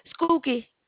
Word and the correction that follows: Skokie: Illinois. – It's Skokie. Skokie, Illinois. – You said Skokie? – Yeah, that's how you say it. Skokie, Illinois.